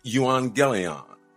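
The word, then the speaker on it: euangelion (εὐαγγέλιον): So the gospel in the original language comes from a word pronounced euangelion. Euangelion. It is E-U and then A-N-G-E-L-I-O-N.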